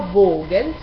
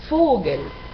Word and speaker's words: Vogel: La V se pronuncia casi siempre, excepto en extranjerismos, como F, Por ejemplo, Vogel, no Vogel, sino Vogel, pájaro.